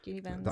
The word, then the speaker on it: Julie Benz: Yes, Julie Benz.